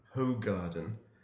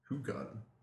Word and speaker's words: Hoegaarden: when we ordered our drinks, I ordered a Hoegaarden, and of course when he came back with it, he said, you know, you do realise it's actually pronounced Hoegaarden, which at the time I didn't know, so that was a genuine point of information.